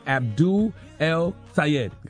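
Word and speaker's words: Abdul El-Sayed: And we have Abdul El Sayed.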